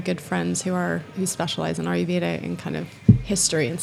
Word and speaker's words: Ayurveda: So it's called Ayurveda.